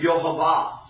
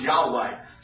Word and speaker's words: Yahweh: But sometimes you'll hear them Yehovah, sometimes Yahweh, sometimes Jehovah.